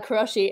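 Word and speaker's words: karōshi: Oh, I probably didn't pronounce it correctly. In your bio. – Yep. – Karoshi? – Yeah, Karoshi.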